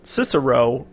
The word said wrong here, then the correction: Sicario: or is that how you say it? – Sicario. Yeah, Sicario.